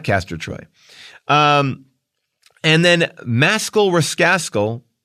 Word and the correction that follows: Maskell the Rascal: oh man, mispronounced that. I think it's Maskell the Rascal, writes, "Omission.